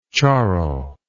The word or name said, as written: charro